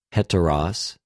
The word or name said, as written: ἑτερος